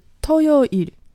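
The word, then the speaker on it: 토요일: 那星期六呢，就是土曜日，也就是 토 요 일。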